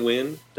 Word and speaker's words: Winn: I'm at Ryan Wynn, WINN, how you spell the last name.